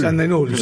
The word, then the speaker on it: Jan De Nul: Jan de Nul, no sé si se dice así, Jan de Nul. – Jan de Nul, sí, la empresa.